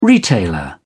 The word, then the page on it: retailer: British English